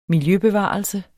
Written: miljøbevarelse

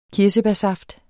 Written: kirsebærsaft

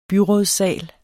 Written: byrådssal

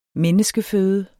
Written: menneskeføde

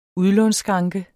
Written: udlånsskranke

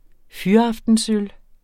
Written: fyraftensøl

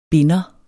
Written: binder

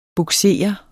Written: bugsere

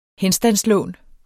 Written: henstandslån

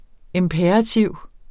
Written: imperativ

